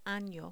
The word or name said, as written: ano